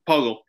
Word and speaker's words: Poeltl: Poeltl.